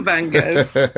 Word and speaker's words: Van Gogh: He would have not got that point if he hadn't said that correctly. – No, Van Gogh. – That's correct.